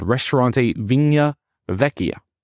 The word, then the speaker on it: Ristorante Vigna Vecchia: I do mispronounce it in my actual conversation, but it is called Ristorante Vigna Vecchia.